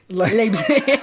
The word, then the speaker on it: Leipzig: Leipzig. – I'll miss it when you eventually learn it. – Yeah. Leipzig. – Just continue. Yeah. – Has managed to make a move to RB Leipzig.